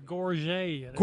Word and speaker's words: gorget: What's it called? Courget? Am I pronouncing it? Come pronounce it for us, because Scott can't hop the bar there. – It's a gorget. – Gorget, okay. – Gorget.